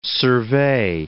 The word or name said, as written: survey